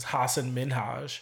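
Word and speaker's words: Hasan Minhaj: So the true pronunciation of his name is Hassan Minhaj.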